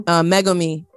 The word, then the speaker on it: Megumi: And I love it because, as we know, it's old boy's Megium. Is how you pronounce his name? – Megumi.